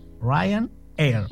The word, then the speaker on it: Ryanair: La empresa se llama Air, Ryanair. Es como, claro, como se escribe con RYA, era rara la pronunciación, pero se dice.